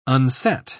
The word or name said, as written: unset